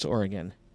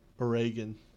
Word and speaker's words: Oregon: How do you pronounce it? – Yes. – Oregon. – No, Oregon. – Is it Oregon? – Oregon. – That's how I say it. – Yeah, it's Oregon. Instead of, like, everywhere else is like Oregon.